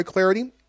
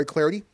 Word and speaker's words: Clardy: I'm Troy Clarity. On Twitter, you can follow me at Troy Clarity. Last name is spelled C-L-A-R-D-Y, at Troy Clarity.